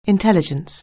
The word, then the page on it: intelligence: anglais